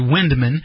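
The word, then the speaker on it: Vindman: This guy, Windman, his name starts with a V, but I think they pronounce it Windman.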